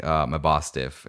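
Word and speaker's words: Mabosstiff: a sick Mabostiff, if I'm saying that correctly.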